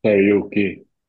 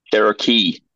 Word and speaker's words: Cherokee: Karaoke. – Cherokee.